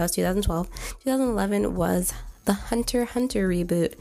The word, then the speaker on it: Hunter x Hunter: So, for those of you who don't know, Hunter Hunter or Hunter X Hunter were you special few who uh, like pronounce the X, um, even though you're not supposed to. It's uh, Hunter Hunter actually had an original series that came out back in like the 80s.